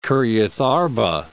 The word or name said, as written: Kirjath-arba